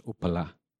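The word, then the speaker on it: uppala: is derived from the Pali word which we would pronounce as Upala.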